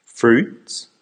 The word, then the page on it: fruits: American English